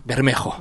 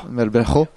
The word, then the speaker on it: Bermejo: que j'ai mal prononcé, non Bermejo. – Bermejo.